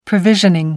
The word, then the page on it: provisioning: American English